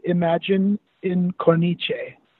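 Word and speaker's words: Immagine in Cornice: however, I was told it's called Imagine in Cornice, which means picture in a frame in so many words.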